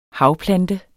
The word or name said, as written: havplante